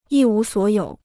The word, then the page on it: 一无所有: yī wú suǒ yǒu